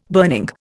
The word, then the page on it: burning: Indian